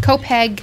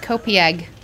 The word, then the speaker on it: Copiague: Long Island, let me just, for my defense, how it's spelled. Copiague. Mm-hmm. Of course, that's pronounced Copeg.